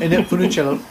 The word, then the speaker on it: Funicello: It's like a trilogy from Annette Funicello. I can't say her name. – Annette Funicello.